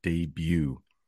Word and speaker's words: debut: Yeah, I know there's a T at the end. I don't know why there is a T at the end. I think it does come from the French, but we pronounce it debut.